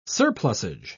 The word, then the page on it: surplusage: British English